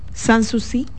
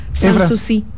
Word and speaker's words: Sanssouci: Sans es sin. – Y no hay que poner la boca ni. – Entónalo, para yo no oír. – Y no hay Sanssouci. Y no se puede, no hay que decir son.